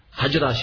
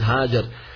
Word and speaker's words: Hajar: Hajra, she asked, but actually the correct pronunciation is Hajar.